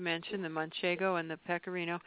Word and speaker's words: pecorino: You know, the things that I've come across are, and I haven't expanded past manchego and pecorino cheese.